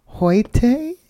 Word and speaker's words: Hoyte: It is Zidina Hoite.